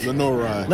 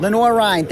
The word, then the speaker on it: Lenoir-Rhyne: Did I pronounce that right? – Lenoir-Rhyne. – Lenoir Rhine, thank you very much.